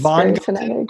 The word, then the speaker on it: Von Gunten: Today, I'm joined by Morgan Van Gunten, who, did I even get Van Gunten? Is that the right pronunciation? – Oh, no, it's Von Gunten. It's Von Gunten.